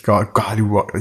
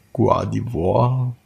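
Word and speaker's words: Guardevoir: Das ist schon ein bisschen, also Schlappohr ist das Guardiwar der äh, spricht jetzt eigentlich Guardiwar. Ich kann, ich kann das nie aussprechen. Guardiwar.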